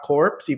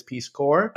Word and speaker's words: Corps: Which was something that we learned very recently, that it's not corpse, even though it's spelled as corpse. It is Peace Corps.